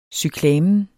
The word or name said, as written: cyklamen